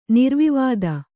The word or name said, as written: ನಿರ್ವಿವಾದ